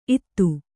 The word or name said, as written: ಇತ್ತು